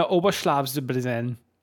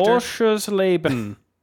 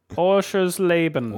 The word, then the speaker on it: Oschersleben: The Motorsport Arena Oberschlafsbrenn. Which I'm sure is how you pronounce that. – Are you okay? – Do you need me to call a doctor? – Oschersleben. Oschersleben. – Oschersleben.